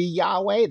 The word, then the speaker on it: Yahweh: Um, so the actual pronunciation of how you say it, we we believe would be Yahweh.